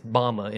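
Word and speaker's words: bamah: That's literally how you pronounce it. It's Bama, and it means the high places where the pagans, where they went to go worship idolaters, or idolatrous worship.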